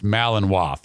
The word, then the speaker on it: Malinois: It is pronounced Malinois.